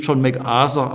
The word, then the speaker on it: MacArthur: Ich zitiere zunächst aus einem Buch von John MacArthur, auf Englisch John MacArthur ausgesprochen, mit dem Titel »Durch die enge Pforte«.